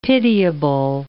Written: pitiable

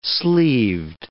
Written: sleeved

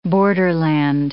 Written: borderland

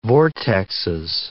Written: vortexes

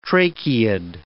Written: tracheid

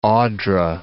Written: Odra

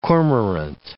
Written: cormorant

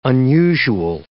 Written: unusual

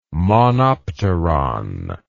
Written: monopteron

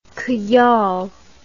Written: khayal

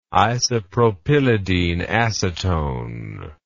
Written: isopropylideneacetone

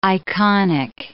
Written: iconic

